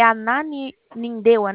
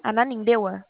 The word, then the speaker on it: Ananindeua: Eu só não sei pronunciar o nome da cidade. É Ananindeua, né? Ananindeua, onde você mora? – É, mas eu não moro mais lá, eu morava quando era junto.